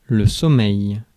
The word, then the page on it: sleep: /sliːp/